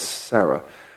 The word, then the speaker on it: Sarah: Cardinal Robert Sara, who some people say his name is Sarah. No, it's not. It's like que Sarah. It's Sarah.